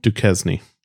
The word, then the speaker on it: Duquesne: Duncaness incline? Am I saying that correctly? – Duquesne. – Oh, sorry. Really terrible. Duquesne. Okay.